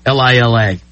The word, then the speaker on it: Lila: Are you pronouncing that Lila or Lila? How do you pronounce that? Lila.